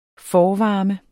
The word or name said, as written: forvarme